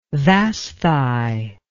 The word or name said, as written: Vasthi